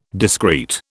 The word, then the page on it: discrete: British English